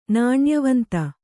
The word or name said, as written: ನಾಣ್ಯವಂತ